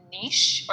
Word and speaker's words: niche: is it niche or niche? – Well, it's both, actually. It's like Caribbean and Caribbean.